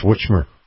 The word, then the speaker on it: Whitmer: You didn't say that right either. It's Whitmer. – Yeah, I said Whitmer. – Yeah, okay, you did. Okay, you got to make sure you get the witch in there.